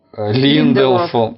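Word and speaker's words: Линделоф: Линделов. Линделов. Линделов. – Ударение на Линделов.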